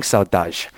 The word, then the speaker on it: Saudade: It's called, let me make sure I'm pronouncing this correct, Crushing Saudade? – It's called Crushing Saudade. Saudade is not an English word. It's a Portuguese word.